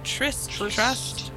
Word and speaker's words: Trust: Trust is from, well, it said Trust, but it's spelled T-R slash S-T. – Yeah, so it's like Trust.